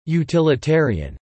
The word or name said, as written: utilitarian